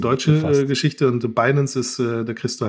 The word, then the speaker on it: Binance: Binance.